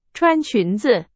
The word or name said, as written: 裙子